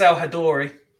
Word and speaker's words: Essam El Hadary: I believe it's El Hadary. – El Hadary then.